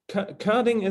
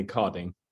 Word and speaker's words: Körding: Yeah, Körding is the German pronunciation, but all Americans call me Kording.